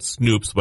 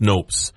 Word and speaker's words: Snopes: Then you can say, or you've gone to a fact checker site, like I call it Snoops, but I think it's pronounced Snopes, Snoopes.